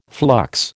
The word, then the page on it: flux: American English